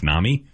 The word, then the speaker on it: NAMI: I'm hanging out here with Kate and Martha from the National Alliance on Mental Illness, which I learned today is pronounced NAMI.